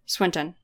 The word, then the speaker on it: Swinton: Is that how you say her name? – Tilda Swinton? – Swinton, yeah.